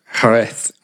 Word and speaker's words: Jerez: And may I just say, Abby, your pronunciation of Jerez was exquisite.